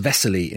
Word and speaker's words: Veselý: Actually, the name Wesley is pronounced Veselý in Czech.